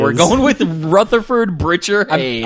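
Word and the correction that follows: Birchard: I'm sorry, I mispronounced it. It's Beerchard.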